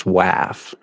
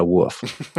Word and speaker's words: WAF: Yeah, a quick note about pronunciation. It's WAF. WAF. It's an acronym, not an initialism. So we pronounce it like a word, like, for example, NASA. So it's a WAF, not a WAF, and definitely not a WOOF.